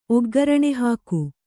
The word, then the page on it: ಒಗ್ಗರಣೆಹಾಕು: oggaraṇe hāku